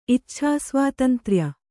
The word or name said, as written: ಇಚ್ಛಾಸ್ವಾತಂತ್ರ್ಯ